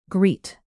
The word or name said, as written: greet